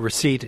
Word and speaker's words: receipt: A receipt is another word like that. – Yeah, it is. The silent P in receipt, the silent B in subtle, the silent L in salmon, and so on.